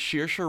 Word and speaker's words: Saoirse: It's Saoirse Ronan and Hosier. – I can't believe you didn't laugh at it. – You can't believe I didn't laugh at it? It's Saoirse Ronan...